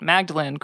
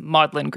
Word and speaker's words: Magdalene: So yeah, so if you're first coming across these comics, you see it makes total sense for you to want to pronounce it as you familiarized yourself with it, Magdalene Grimoire, but it's Maudlin Grimoire.